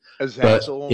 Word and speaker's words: Azazel: But he said it should be translated as Azazel. Sorry, I might be mispronouncing that. – Azazel is fine.